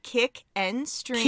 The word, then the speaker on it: Kick and Stream: Follow us on the Twitter at Kick and Stream. – Kick in the door at Twitter. – No. – Kick in Stream. – We're not getting started until you say it right. – Kick and Stream? – Yes.